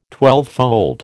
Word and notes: twelvefold: American English

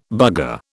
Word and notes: bugger: British English

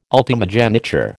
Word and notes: ultimogeniture: American English

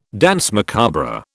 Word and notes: danse macabre: British English

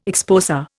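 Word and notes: exposé: Indian

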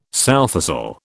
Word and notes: sowthistle: British English

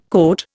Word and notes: coat: Indian

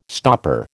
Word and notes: stopper: American English

